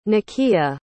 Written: nikia

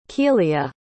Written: Keelia

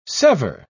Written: sever

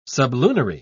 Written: sublunary

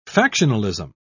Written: factionalism

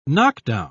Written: knockdown

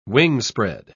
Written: wingspread

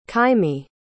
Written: kaimi

